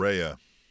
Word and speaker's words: Saraya: Saraya.